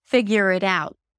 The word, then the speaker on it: figure it out: The T at the end is just a held T or a very light T, so you don't have to overly pronounce it. Figure it out.